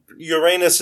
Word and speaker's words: Uranus: And Uranus is the correct pronunciation. I'm not just being obnoxious.